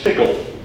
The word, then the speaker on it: TCL: Another differentiating feature of the Newport HXP Hexapod is the ability to issue TCL, pronounced tickle, commands found in the terminal mode.